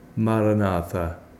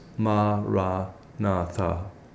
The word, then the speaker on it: Maranatha: Maranatha, meaning Lord come. It is short and simple, just 4 syllables. Thus, Maranatha.